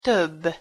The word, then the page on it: tobb: Hungarian